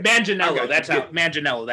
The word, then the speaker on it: Manganiello: Manganiello. That's how it's pronounced.